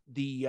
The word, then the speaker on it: DIC: It was produced by Columbia Pictures Television and Deke Enterprises. And Deke, if it's like D-I-C, but it's pronounced Deke, not what you think.